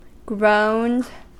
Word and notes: groaned: American English

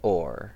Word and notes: oar: American English

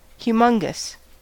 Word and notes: humongous: American English

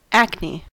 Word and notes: acne: American English

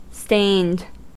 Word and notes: stained: American English

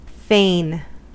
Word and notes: fain: American English

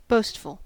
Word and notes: boastful: American English

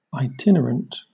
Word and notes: itinerant: British English

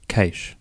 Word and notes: cache: Australian English

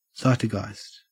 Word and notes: zeitgeist: Australian English

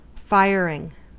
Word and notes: firing: American English

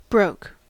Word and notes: broke: American English

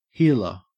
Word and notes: heeler: Australian English

